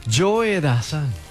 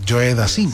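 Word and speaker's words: Joe Dassin: Y entonces presentó a Joe Dazan como si fuera Joe Dazin, como se escribe. Joe Dazin. Entonces yo le dije, no olvides Dianilla, que es Joe Dazan.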